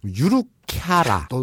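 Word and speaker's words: 유루캬라 (ゆるキャラ): 유루캬라. 너도 발음이 잘 안 된다. 네. 해봐. 유루카리아. 아, 어, 그러게 어렵다. 어렵지. 유루캬라. 어, 그렇지.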